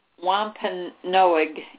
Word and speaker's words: Wampanoag: I hope I say this right. The Wampanoag Indians, it's Wampanoag, Wampanoag Indians were the first people who taught the Pilgrims how to cultivate the land.